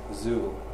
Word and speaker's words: XUL: The next technology is XUL, pronounced Zool.